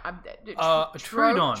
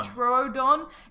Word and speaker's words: Troodon: Now, Troodon. – Troodon. It's spelled T-R-O-O-D-O-N.